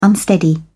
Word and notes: unsteady: British English

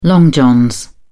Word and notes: long johns: British English

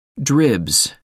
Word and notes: dribs: American English

